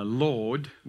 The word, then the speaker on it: laud: When Lisa was talking about uh, Lord, which is actually how you pronounce it, Lisa, I'm just saying, um, L A U D, it's, uh, it's interesting.